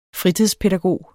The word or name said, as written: fritidspædagog